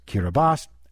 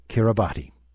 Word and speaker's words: Kiribati: In the local language, T-I is pronounced as an S, so in the islands, it's Kiribati. Outside, most people say Kiribati.